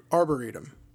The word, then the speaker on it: Arboretum: So the second game new to the collection is Arboretum. I think that's how you say it. I heard Norm talking about it, and that's how he says it. So Arboretum.